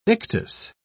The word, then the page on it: ictus: British English